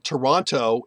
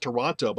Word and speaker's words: Toronto: So, I mean, we were talking earlier just before the show, and Toronto is never pronounced Toronto by people who live here. We take out the T and it's Toronto.